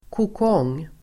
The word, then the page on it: kokong: [kåk'ång:]